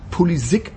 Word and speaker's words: Pulisic: Ich habe tatsächlich das Glück gehabt, ähm, ich sag's mal so, einen Herrn kennenzulernen in Dortmund, der sagte, dass er Christian Pulisic heißt. – Der hat dann aber irgendwann beschlossen, das war diese Geschichte mit der Internationalisierung seiner Marke, ja, Polisik ausgesprochen werden zu wollen.